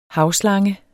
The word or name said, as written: havslangen